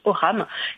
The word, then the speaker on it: ogham: Oui, il s'agit d'un alphabet, plus particulièrement, qui s'appelle l'Oham, ça s'écrit O-G-H-A-M, ça se prononce Oham,